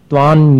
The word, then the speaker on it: त्वां: इसके आगे वाला काव्य है, त्वां योगिनो जिन सदा परमात्म रूप मन्वेषयन्ति हृदयाम्बुज कोश देशे पूतस्य निर्मल रुचेर यदि वाक्य मन्य दक्षस्य संभव पदन ननु कर्णिकायाः। त्वां योगिनो, संयम जैसा अनुस्वार का उच्चारण होगा, त्वां योगिनो जिन सदा परमात्म रूप मन्वेषयन्ति हृदयाम्बुज कोश देशे पूतस्य निर्मल रुचेर यदि वाक्य मन्य दक्षस्य संभव पदन ननु कर्णिकायाः।